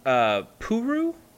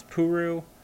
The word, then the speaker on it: Ple: It's something I haven't been able to really wrap my head around in the Gundam series, but it's pronounced Puru. But it's spelled P-L-E, which is not how you pronounce Puru. But I think it's more of a Japanese pronunciation since they have trouble with L's.